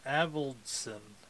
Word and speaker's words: Avildsen: And directed by Avildsen? I can't pronounce last names. – Yeah, Avildsen, I would say.